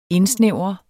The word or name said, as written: indsnævre